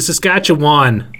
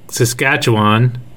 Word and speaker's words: Saskatchewan: I think Saskatchewan is easy. Is the same. I don't see a difference. Saskatchewan. It's very easy. It's not hard. They just say it wrong. It's an A, not an I.